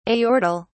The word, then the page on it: aortal: British English